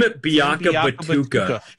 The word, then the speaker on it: Biakabatuka: Tim Biakabatuka.